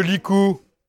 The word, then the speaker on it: licou: Les bras nus, bon, les bras c'est facile, B-R-A-S, nu, au pluriel, N-U-S. Retenait. Qui retenait? Les charretiers. A-I-E-N-T. Retenez par le Likou. Le Likou. Ah ah. Comme ça se prononce, tout simplement, Likou. L-I-C-O-U.